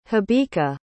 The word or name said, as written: habika